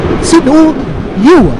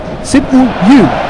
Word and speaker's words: Sinu Yu: Und ich habe mir eben noch extra sagen lassen, wie er ausgesprochen wird. Und zwar wird er ganz korrekt ausgesprochen. Sinu Yu. So wird er ausgesprochen. Also falls ihr euer Südkoreanisch verbessern wollt, Sinu Yu.